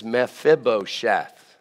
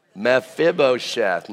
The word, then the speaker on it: Mephibosheth: His name is Mephibosheth. Say that. Mephibosheth.